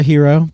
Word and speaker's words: Gyro: So the quick service is called Zero to Hero, but it's spelled G-Y-R-O.